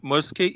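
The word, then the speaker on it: Musker: é que é o primeiro filme dirigido pelo John Musk, Muskey e o Ron Clements. Falei certo, Alan? Falei besteira. – Não, é isso mesmo.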